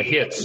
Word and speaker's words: Hits: It's actually Hits, just like Hits. A Ritz cracker.